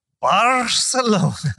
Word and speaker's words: Barcelona: Enough Expedia points to get the two of them to Barcelona. – You don't roll that R though.